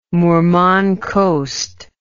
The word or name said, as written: Murman Coast